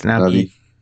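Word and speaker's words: Na'vi: How do you say it? It's Navi.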